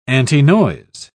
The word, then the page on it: antinoise: British English